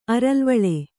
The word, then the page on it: ಅರಲ್ವಳೆ: kannada